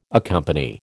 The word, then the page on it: accompany: American English